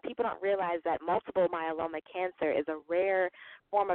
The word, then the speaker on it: myeloma: is it the Myola Cancer Awareness? Am I saying that correctly? – So, yes, it's Multiple myeloma cancer, yes.